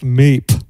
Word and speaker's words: Miep: His name was M-I-E-P, right? And he goes, Father, this is Miep, wants to say a few words. And he went up to the MC and says, it's pronounced Meep.